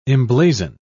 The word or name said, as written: emblazon